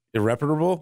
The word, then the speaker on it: irreparable: Irreparable? – You've been doing that more often. – I said it right? – Irreparable, yeah.